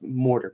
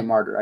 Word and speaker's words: martyr: I always said it, like, mortar, but now I say a martyr. I say it right.